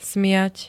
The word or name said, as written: smiať